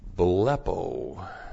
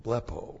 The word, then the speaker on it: blepo: Blepo. B-L-E-P, long O, blepo.